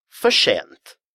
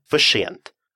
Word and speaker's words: för sent: Слово «för» заканчивается на «r». Слово «sent» начинается на «s». А буквосочетание «rs» в шведском языке читается как «sh». И это распространяется не только на отдельные слова, но и на такие ситуации, когда одно слово заканчивается на «r», а другое на «s». Ведь речь в шведском языке должна быть единой волной изливаться из ваших уст. Поэтому такое сочетание «fashent» абсолютно допустимо и абсолютно натурально.